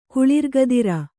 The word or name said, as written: ಕುಳಿರ್ಗದಿರ